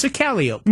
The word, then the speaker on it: calliope: It's not calliope. – No, it's a calliope.